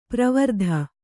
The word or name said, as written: ಪ್ರವರ್ಧ